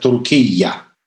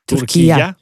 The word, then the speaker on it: Туркия: Это теперь нужно произносить как «Туркия». – Туркия.